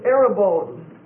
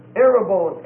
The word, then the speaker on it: arrabon: Okay, the next word is arabone. Arabone. A-R-R-A-B-O-N. Arabone. A-R-R-A-B-O-N. And it's a long O.